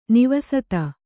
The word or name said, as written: ನಿವಸತ